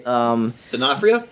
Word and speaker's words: D'Onofrio: Vincent, how hard time pronouncing his last name. The guy from Full Metal Jacket. – D'Onofrio? – Yep, that's it.